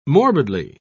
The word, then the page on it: morbidly: British English